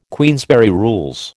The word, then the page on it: Queensberry Rules: American English